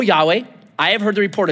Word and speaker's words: Yahweh: So whenever I see capital L, capital O, capital R, capital D, I just change it to the best way we know how to pronounce it, Yahweh. Some people say Jehovah. I think Yahweh is correct. So, freebie. O Yahweh, if it's not capital L-O-R-D, if it's capital L, little O-R-D, it's most likely Adonai.